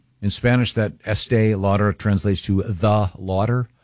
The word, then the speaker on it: Estee Lauder: Is it pronounced Estee Lauder? Estee Lauder, yes.